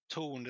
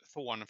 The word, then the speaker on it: Thorn: Torn, det ska uttalas Thorn, för det är ett engelskt ord för, för den koncernen, men vi, vi sa alltid Torn hyr-tv.